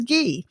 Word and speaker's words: ghee: Spelled G-H-E-E, pronounced ghee.